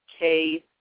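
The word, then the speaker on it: Usekh: Wusek, Wusekh, or just U-S as in Sam, E-K-H, Wusek. The U is pronounced like a W sound, Wusek.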